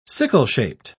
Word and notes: sickle-shaped: British English

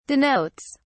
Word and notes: denotes: British English

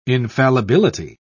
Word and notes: infallibility: British English

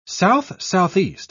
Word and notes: south-southeast: British English

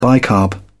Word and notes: bicarb: British English

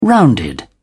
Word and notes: rounded: British English

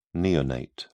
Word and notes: neonate: British English

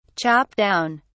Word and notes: chop down: British English

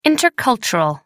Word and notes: intercultural: American English

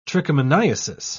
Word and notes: trichomoniasis: British English

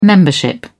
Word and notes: membership: British English

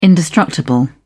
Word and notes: indestructible: British English

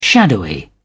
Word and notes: shadowy: British English

